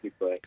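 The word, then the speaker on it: Fuquay: And is it Fuqua Verena? I always forget how to pronounce this in North Carolina. – You got it. Fuqua.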